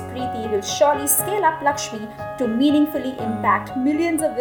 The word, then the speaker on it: LXME: And her most recent venture and passion is Lakshmi, which is spelled as LXME.